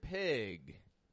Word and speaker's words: pig: And one thing I'd like to point out is notice how I stretch out the I in the word pig. Pig. It's not like the E in beef, but it's an I. Pig. What we call a short I sound.